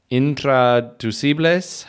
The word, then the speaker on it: intraducible: And we could have done a 3-hour episode on animal idioms and phrases, or animal untranslatables, better said, or intraducibles. How do you say it? – Intraducible. Yep.